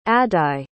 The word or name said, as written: addae